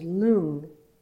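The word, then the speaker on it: rlung: So wind in Tibetan is lung. Energy from more of the Chinese tradition is qi. – What did you say, lung? – Lung. It's L-O-O-N-G-E. And sometimes it's spelled L-U-N-G-E. Lung.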